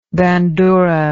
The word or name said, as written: bandura